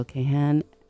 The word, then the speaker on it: Kahane: But what a treat today to have Dr. Mary Palmer and Gabriel Kahane um, did I say that right? – Kahane.